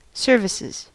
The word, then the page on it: services: American English